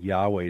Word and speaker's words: Yahweh: Most scholars think Yahweh is the more likely way to pronounce it.